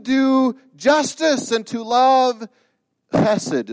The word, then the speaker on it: chesed: It's that word chesed. Everybody say that with me. Chesed. Okay, you kind of got to go, it's a guttural. You got to go from your throat to say it properly.